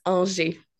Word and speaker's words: Angers: Almost there. It's Angier.